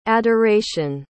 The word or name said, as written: adoracion